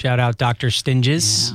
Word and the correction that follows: Tinges: Yeah. Tinges. – Tinges. – Tinges, yeah. – I butchered that name.